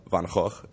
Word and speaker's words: Van Gogh: Just for me, I love Van Gogh, as you said.